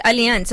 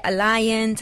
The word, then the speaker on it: Allianz: No, it's Allianz. So some people say Alliance, some people say but it's pronounced Allianz.